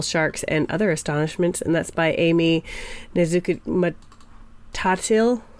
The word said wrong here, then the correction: Nezhukumatatil: It's Nezhukumatatil. – Yep. – Nezhukumatatil, I think.